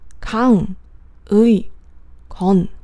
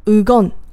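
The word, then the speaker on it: Kang Ui Kon (강의건): 改之前呢，他的名字叫做江义健，韩文的发音呢就是 Kang Ui Kon。你可以想象一下这个名字有多难念，Kang Ui Kon。他自己说，因为周围很多人都念不对他的名字，所以很闹心。包括连他自己的爸爸有时候都会念错，念成什么 Ugon 之类的。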